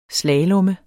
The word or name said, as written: slalom